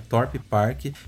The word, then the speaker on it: Thorpe Park: o nome do parque é Torp Park. Se escreve assim: T-H-O-R-P-E-P-A-R-K.